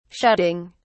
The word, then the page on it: shutting: British English